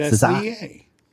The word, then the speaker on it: SZA: Or SZA. – So that's what they meant. – SZA. I don't know. – How you say it? – Zaza? – It's SZA.